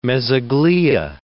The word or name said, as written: mesogloea